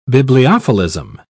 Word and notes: bibliophilism: British English

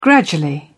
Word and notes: gradually: British English

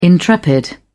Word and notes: intrepid: British English

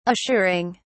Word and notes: assuring: British English

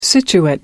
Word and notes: situate: American English

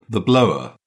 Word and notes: blower: British English

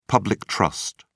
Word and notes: public trust: British English

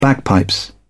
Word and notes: bagpipes: British English

